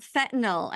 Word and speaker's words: fentanyl: You pronounced it correctly. Fentanyl. – Okay. – So it has actually two correct spellings, F-E-N-T-A-N-Y-L or N-I-L.